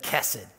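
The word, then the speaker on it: kesed (חסד): I was joking around last week because I think you pronounce it differently, but my Hebrew professor said kesed. So I was like, kesed. Doesn't matter, it's just being north and south, who knows.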